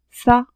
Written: ca

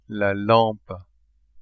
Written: lampe

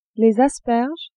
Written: asperges

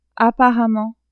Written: apparemment

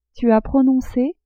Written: tu as prononce

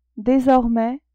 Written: desormais

